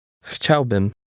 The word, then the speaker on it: Chciałbym: Chciałbym. Bym. Bym. Chciał. Chciał. Chciałbym. Chciałbym. – Did you hear the M sound at the end?